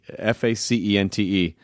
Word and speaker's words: Facente: His name was Jim Facenti, F A C E N T E. It should be pronounced Facente, but uh, the Italians wanted to fit in with the Americans.